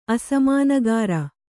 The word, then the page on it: ಅಸಮಾನಗಿರಿ: asamānagiri